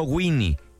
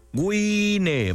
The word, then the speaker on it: Wine: Se escribe Baja Wine, no Winnie. Wine, Baja Wine, Baja Wine, presentando Omelette Sensacional.